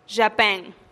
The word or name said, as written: xapen